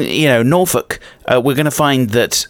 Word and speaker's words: Norfolk: I make the point that you don't pronounce Norfolk, you pronounce it Norfolk.